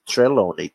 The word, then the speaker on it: Trelawney: Tá, gente, Trelawney, tá? A pronúncia. Mas se quiser falar Trelawney, pode.